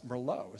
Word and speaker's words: Merlot: It's Merlot is how you pronounce it, for those that don't know.